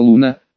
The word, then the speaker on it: Луна: «Слишком долго, давай тогда просто Луна, с ударением на У».